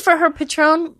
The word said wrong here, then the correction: Patreon: or am I saying it right? Patreon? – Patreon. – Patron. I'm thinking of tequila.